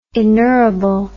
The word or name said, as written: inerrable